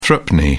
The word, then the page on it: threepenny: British English